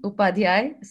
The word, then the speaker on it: Upadhyay: Well, okay, my family name Upadhyay, so that's how it's pronounced, and I don't get offended if anyone cannot say it right.